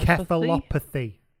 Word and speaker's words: encephalopathy: Chronic uh, traumatic emphysopathy. Encephalopathy encephalopathy. There we go.